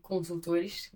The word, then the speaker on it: Consultores: I'll translate to make it easier, but the name of the company is JD Consultores, então JD Consultores. That's how you say it in Portuguese.